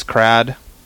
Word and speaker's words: Krad: All right, well, my name is Crad. Don't go saying that around here. – How do you spell that? – K-R-A-D.